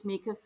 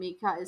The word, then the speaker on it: Mieka: It's Mieka Phoenix on Instagram. And Mieka is spelled M-I-E-K-A and then Phoenix.